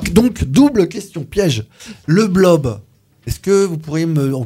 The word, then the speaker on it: blob: Et qu'elle est devenue célèbre dans le monde entier autour de ses travaux sur le fameux blob. – Exactement. – Je prononce bien ou pas? – Oui, c'est ça.